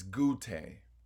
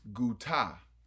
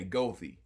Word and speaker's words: Goethe: If you were to say his name in German, it's Goethe. But if you say his name in English, it's pronounced Guta. But because we Detroiters know how to really say your name. He's got a street named after it that runs through our neighborhood, and we say Gothy.